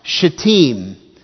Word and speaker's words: Shittim: There is a place called the Acacia Grove. It's pronounced Shittim.